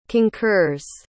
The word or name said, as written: concurs